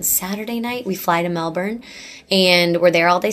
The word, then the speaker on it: Melbourne: Australians, the tip is you've got to say it as lazily as possible. And that's probably how Australians say it. – Melbourne. – Yeah. – Melbourne.